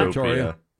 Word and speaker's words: Pretoria: Pretoria is the capital of which country? And I might be saying that wrong. – Fake-tropia. Pretoria. – You're saying it right.